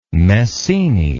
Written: messene

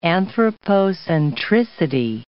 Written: anthropocentricity

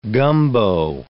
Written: gumbo

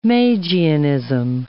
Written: Magianism

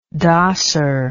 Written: dosser

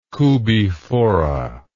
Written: Koobi Fora

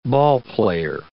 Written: ballplayer

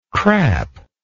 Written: crap

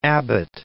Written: Abbott

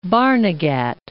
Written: Barnegat Bay

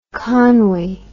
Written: conwy

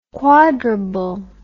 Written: quadrable

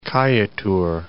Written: Kaieteur Falls

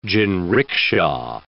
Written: jinrikisha

